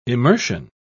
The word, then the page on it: immersion: British English